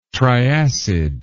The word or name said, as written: triacid